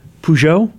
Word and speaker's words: Peugeot: Peugeot? – Mm-hmm.